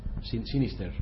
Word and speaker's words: Sinister: Yo, fíjate que tengo ganas de ver, no sé si habéis visto, mm, Sinister. sinister, bien pronunciado, Sinister.